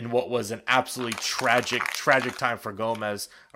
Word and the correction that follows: Gomes: For Gomes, I keep saying his name wrong.